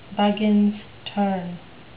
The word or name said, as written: buggins turn